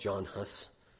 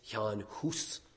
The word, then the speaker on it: Jan Hus: Sometimes he's called John Hus, but if we're going to pronounce his name right, it's Jan Hus.